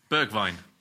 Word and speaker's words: Bergwijn: Bergwijn.